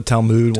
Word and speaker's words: Talmud: Talmud.